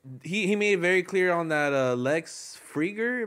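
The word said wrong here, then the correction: Fridman: That, like, Friedman.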